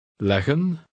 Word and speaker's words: leggen: Read word 9 twice, with and then without the final N. Note that you pronounce only one G. – Leggen, leggen.